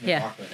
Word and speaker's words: McLaughlin: Oh, I said it wrong. It's McLaughlin.